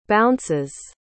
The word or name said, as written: bounces